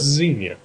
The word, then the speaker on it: Xenia: Oh, Zinnia. Yeah. I like how you say it a lot better than how everybody else says it. – How do they say ex-Zinnia? – Kathleen calls it X-Town. – It's Xenia.